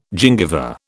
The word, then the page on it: gingiva: British English